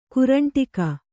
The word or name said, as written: ಕರಂಡಕ